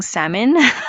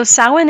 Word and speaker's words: Samhain: So for the longest, I was saying salmon. and then I saw Outlander and I was like, oh, that's how you pronounce it. Okay, got it. So Samhain is a pagan festival originating from an ancient Celtic spiritual tradition.